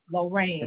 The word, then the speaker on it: Lorain: And then Leon Street, and I'm mispronouncing Lorraine. It. Lorraine. – It's spelled L-O-R-A-I-N.